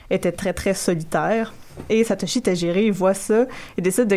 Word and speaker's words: Satoshi Tajiri: Donc, le créateur Satoshi Tajiri, pardonnez ma prononciation du japonais, parfait.